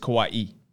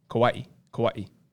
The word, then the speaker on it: Kauai: It's Kauai. Kauai. – As opposed to Hawaii. – No, as no the island of Kauai. – Oh, got it. – Anyways, you got to pronounce them differently. You know what I'm saying? It's Kauai.